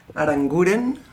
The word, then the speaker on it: Aranguren: Aranguren.